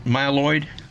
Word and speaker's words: myeloid: Okay, and so in 2010, Earl was diagnosed with acute myeloid. Is that how you say it? Myeloid? – Yeah, acute myeloid leukemia, AML.